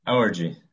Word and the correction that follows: Elegy: right? – Elegy? Elegy? – Oh, Elegy. Sorry. No, Elegy.